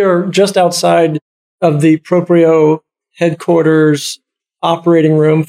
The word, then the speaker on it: Proprio: He's from Proprio. Am I pronouncing correctly? – Proprio, yep.